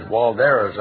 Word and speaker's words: Waldera: But Waldera is spelled W-A-L-D-E-R-A, but the German doesn't allow you to pronounce the W as wa. It's va.